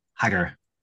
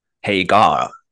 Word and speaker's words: Hagar: Is Hagar or is it Hagar? – Hagar. – Hagar. – Hagar. At the Hagar. Hagar. Yes. Yeah. It's a weird one to pronounce, but yeah, I am Hagar, as in Hagar the Horrible, the Viking.